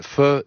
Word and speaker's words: pho: Alors là, on est au nord du Vietnam et on va goûter un pho. C'est comme ça qu'on prononce pho ? – Pho. – Pho. – Pho. – Oui. En aucun cas, c'est faux. – Non. – C'est faux de dire faux.